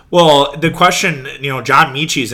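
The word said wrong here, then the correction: Metchie: another one. Mechie, I should say, mispronounced there.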